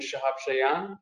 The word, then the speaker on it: Shahab Shayan: My name is Shahab Shayan.